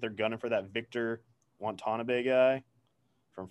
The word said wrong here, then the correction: Wembanyama: Uh, I don't know if I'm pronouncing that name right, Dan. That's where you come in. – It's Wembanyama.